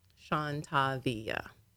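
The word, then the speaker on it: Shawntaviya: So the Sean is S-H-A-W-N. – Okay. – And then the Tavia is T-A-V-I-Y-A. Sean Tavia.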